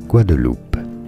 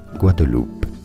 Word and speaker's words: Guadeloupe: In French, it said as Guadeloupe. It's a département of France. Guadeloupe. In English, you can say it as Guadeloupe. Guadeloupe is how would you say it. It's easy, and now you know.